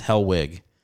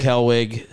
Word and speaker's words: Hellwig: I got a thing I say with my name because it's Hellwig. So I go Eric Hellwig, CK for Eric. Hell like the place, wig like a thing on your head.